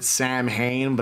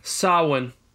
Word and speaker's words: Samhain: Most people pronounce it Samhain, but I believe it's right. It's pronounced Samhain or Samhain. – Samhain.